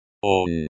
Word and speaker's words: Он: Он. He, pronoun, masculine, nominative case. This word is pronounced like the English word, on, but with a short, O, sound.